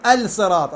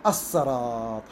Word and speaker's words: الصراط: أقول، ما أقول الرحمن، أقول الرحمن، ما أقول الرحيم، أقول الرحيم، ما أقول الصراط، أقول الصراط، هذا واجب الإدغام، يعني اللام بعد تدغم بالصاد، يعني لا تلفظ وتشد، يشدد الحرف الذي بعده.